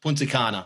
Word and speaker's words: Punta Cana: So it's the Carlos Punta Cana. – Punta Cana. Is that how you say it? – Yes.